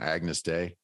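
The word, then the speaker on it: Agnus Dei: And I mean, it's funny that you mentioned a little earlier about, I'm probably going to pronounce it wrong, Agnes Day.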